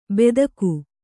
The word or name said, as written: ಬೆದಕು